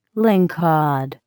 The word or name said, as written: lingcod